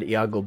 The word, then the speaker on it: Iago: Is it Iago? Is that how you say it? – It is Iago, indeed.